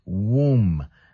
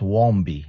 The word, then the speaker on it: womb: So it's W-O-M-B. Utero, right? So it's womb. The B is silent. And the beginning of the word is like woman. It's not wombi. No, no, not wombi. It's womb. And the B silent.